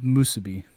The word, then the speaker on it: Musubi: Your choices are Musubi. Did I pronounce that correct? – You did. Very good. – And points for Joey. Yes, and mustache wax. – Let's go with Musubi.